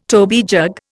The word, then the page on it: toby jug: Indian